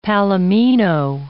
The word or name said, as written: palomino